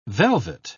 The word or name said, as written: velvet